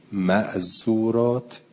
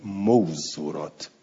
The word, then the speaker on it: معذور: یعنی امام علیه السلام به قرینه اون کلمه این کلمه رو اینجوری تلفظ کرده، در حالی که مردم میگویند موزورات. مردم میگویند موزور و ما باید بگیم موزور، اما تو حدیث گفته معذور.